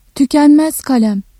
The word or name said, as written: tükenmez kalem